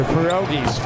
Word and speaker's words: pierogies: Watch the pierogies, Piaggies. They're getting corrected by Vanessa in the truck. Yeah, how do you say it, Vanessa? I know people at home can't hear you. Pierogies. We said Piaggies.